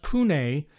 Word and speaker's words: CVNE: Now it's spelled CVNE. It's all capitals, but it's actually pronounced Cune Monopole White Rioja, and it's 100%.